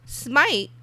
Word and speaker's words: Smyte: Smite, which is spelled S-M-Y-T-E, was founded in 2014 by former Google and Instagram engineers.